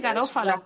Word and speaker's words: Garofalo: Yo lo llamo siempre Garofalo. – Yo creo que es Garófalo. – Es como Garófalo. – Sí, yo creo que es Garófalo, que es como con el acentito, ¿sabes?